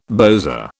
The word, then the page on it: bowser: British English